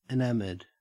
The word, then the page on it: enamoured: Australian English